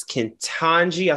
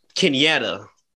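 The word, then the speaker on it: Ketanji: So, today was confirmed that Miss Kentanji, I said her name right. – Yeah. – Okay, I've been messing her name up. I just been calling her Miss Jackson, okay? Because I've been messing her name up. Because I seen somebody else call her Kenyatta. – Not Kenyatta. – I see. That is not that woman's name. – That ain't even close.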